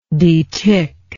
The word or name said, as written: deticker